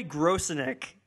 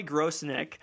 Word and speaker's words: Grosenick: Did you say Grosinek? – Grosinek? Grosinek? Grosinek? – No D. No D? – No. No D. No D. No gross dick.